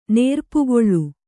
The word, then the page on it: ನೇರ್ಪುಗೊಳ್: nērpugoḷ